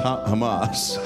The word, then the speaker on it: Hamas: Yamas is the shit. Who the fuck is Yamas? – That's pronounced Hamas.